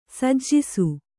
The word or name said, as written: ಸಜ್ಜಿಸು